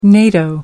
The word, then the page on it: NATO: American English